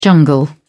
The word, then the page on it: jungle: British English